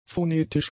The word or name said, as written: phonetisch